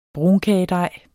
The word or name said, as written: brunkagedej